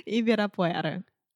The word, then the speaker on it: Ibirapuera: Ibirapuera.